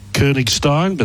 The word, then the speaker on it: Koenigstein: Don't think the language allows for the umlaut on Koenigstein, but that's the pronunciation.